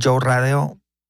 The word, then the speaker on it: Joe Radeo: Pues en mis redes sociales, tal cual como se escucha mi nombre, Joe Radeo, J-O-E, se escribe J-O-E, Radeo, R-A-D-E-O.